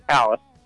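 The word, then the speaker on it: Alys Beach: It's called Alice Beach, and it's spelled A-L-Y-S, but it's pronounced Alice.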